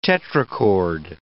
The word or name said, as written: tetrachord